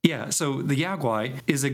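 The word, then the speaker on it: Yao guai: But the Yaogwai, which is how I pronounce it, I think it's pronounced, because like when I was a kid, I always called it the Yaogui because it is spelled with,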